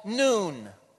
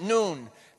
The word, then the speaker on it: Nun: The Arabic letter is called Nun. That's how you pronounce it, Nun.